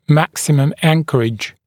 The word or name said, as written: maximum anchorage